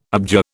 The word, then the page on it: abjure: British English